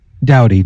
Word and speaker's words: Doughty: My question, now that they won it, and I know how competitive the players are, I can't say his name, Drew Doughty? – Doughty, Drew Doughty, yeah.